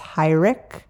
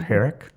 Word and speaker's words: Heurich: So it's also probably the reason why and why people still to this day mispronounce his name a lot, that it doesn't look like it should be pronounced Heurich. Mm-hmm. That's not the German pronunciation. You probably can say the German pronunciation better than I can. – Heurich? – Heurich, yeah.